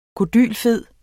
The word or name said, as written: kodylfed